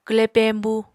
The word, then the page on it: kwle peñbu: Apatani